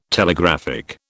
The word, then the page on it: Telegraphic: British English